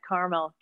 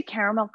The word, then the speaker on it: caramel: Do you say caramel, or how do you say it? – Caramel? Is that a Delaware thing? Caramel? – Caramel, I think caramel. – So. – I've never quite given it 3. Caramel. No, I have never caramel. Given it 3. Caramel. It's always been 2.